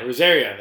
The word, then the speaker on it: Rosario: Yeah, Rosario.